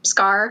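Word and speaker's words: Skaar: Because, like, I saw a tweet the other, because, like, people were discussing Scar, is that how you pronounce the Hulk's son's name? – Yes.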